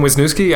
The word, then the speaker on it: Wisniewski: My name is Tim Wisniewski.